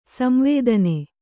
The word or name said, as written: ಸಂವೇದನ